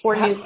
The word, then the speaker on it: Fornue: Yes, it's Fornew Carson. – How do I spell it? – F-O-R-N-U-E, and then it's a hyphen, Carson.